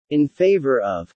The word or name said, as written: in favor of